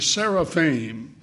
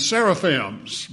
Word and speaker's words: seraphim: They're also in the Bible in the form of those two words that many people mispronounce, cherubim and seraphim. Familiar with those words? I've heard people say cherubims and seraphims, but the I-M on the end of those words in the Hebrew is plural already.